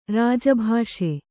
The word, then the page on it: ರಾಜಭಾಷೆ: Kannada